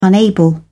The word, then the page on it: unable: British English